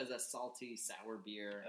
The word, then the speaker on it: Bier: Beer, B-I-E-R, German spelling. – So is B-I-E-R pronounced buyer or just beer? – Beer.